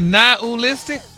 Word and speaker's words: nihilistic: Nihilistic? – Wow. – Stop putting the ooh in there.